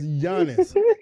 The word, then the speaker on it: Giannis: I know it's Giannis.